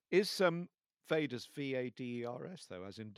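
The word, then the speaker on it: Vaidas: It's it's V. I'm probably not pronouncing right. Uh, we all say Vader's. Uh, it's V A I D A S.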